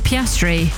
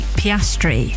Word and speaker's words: Piastri: I was once going to meet Piastri with my partner Stuart, and I was like, how are we going to remember how to pronounce his name? So I had to just sing that song, Pia, Pia, Piano, Piano, Piano, until I met him, and then I could guarantee I was going to say his name correctly.